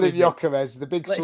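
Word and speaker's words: Gyökeres: How do you say that last name? – Oh, yeah, so we call him Jokeres. – Okay. What did I say? Jokeres. – Yeah, but if you play FIFA, they say Gorkeres.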